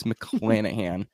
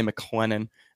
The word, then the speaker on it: McClanahan: It's McClanahan. I always want to say McClennan.